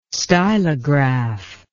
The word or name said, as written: stylograph